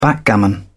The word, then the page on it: backgammon: British English